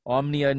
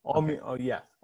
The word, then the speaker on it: Omonia: Omnia Nicosia, is that right? – Yeah. Omonia.